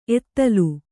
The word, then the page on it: ಎತ್ತಲ್: Kannada